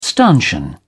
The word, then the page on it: stanchion: British English